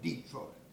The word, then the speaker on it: Detroit: See, we always call it Detroit. Like pulpit, you know, you got to put the emphasis on the first one.